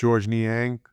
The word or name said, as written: Georges Niang